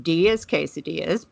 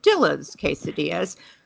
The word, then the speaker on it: Dilla's Quesadillas: It may look like it's pronounced D as quesadillas, but it is actually said Dilla's Quesadillas.